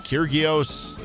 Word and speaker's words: Kyrgios: I don't know how to pronounce these two players' last name, like Nick Kyrgios or something like that.